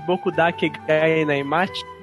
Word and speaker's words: Boku dake ga inai machi: Porque é relevante falar de Bokudake Gai Naimati.